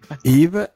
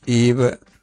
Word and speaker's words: Yves: che Yves è scritto Yves con la Y, sì. – ma non si pronunciano sì. mai le ultime due lettere.